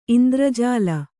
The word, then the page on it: ಇಂದ್ರಜಾಲ: indrajāla